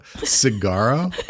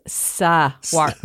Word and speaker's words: Saguaro: Cigarro. – It's not swa. It's Saguaro.